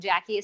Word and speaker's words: Jacquie: So my name Jackie is spelled very weird.